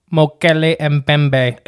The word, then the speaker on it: Mokèlé-mbèmbé: And that's how they say it? – Yeah. Mokèlé-mbèmbé. – It sounds like the automated voice, you know, like a text reader. – Leave a message after the beep. Mokèlé-mbèmbé.